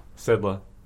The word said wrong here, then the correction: Siddle: So close. Siddle.